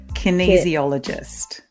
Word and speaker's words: kinesiologist: How do you say it? – Kinesiologist. Kinesiologist. Kinesiologist. Easy. kinesiologist. Oh, kinesiologist. Okay.